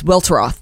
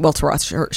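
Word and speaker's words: Welteroth: It's Weltroth. Yeah, that is how you pronounce it. Weltroth. She says it in the book.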